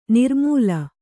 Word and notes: ನಿರ್ಮೂಲ: nirmūla

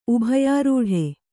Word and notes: ಉಭಯಾರೂಢ: ubhayārūḍha; Kannada

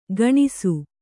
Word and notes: ಗಣಿಸು: gaṇisu

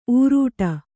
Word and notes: ಊರೂಟ: ūrūṭa

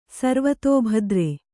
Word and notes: ಸರ್ವತೋಭದ್ರ: sarvatō bhadra; Kannada

